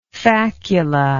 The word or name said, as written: faculous